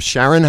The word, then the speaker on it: Charon: Everybody needs to hear the song "Sales of Sharon." How do you say it? – Sharon.